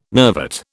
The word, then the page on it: nervate: British English